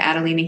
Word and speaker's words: Adalina: My name is spelled A-D-A-L-I-N-A, east like the direction. Or connect with me on Facebook at Adelina Healing.